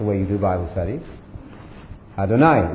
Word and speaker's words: Adonai: You all know about Adonai because it rhymes with El Shaddai.